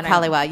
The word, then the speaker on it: Kaliwa: Is it Kaliwa? That's the other one. – Oh, Kaliwa, yes.